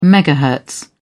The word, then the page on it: megahertz: British English